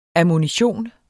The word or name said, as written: ammunition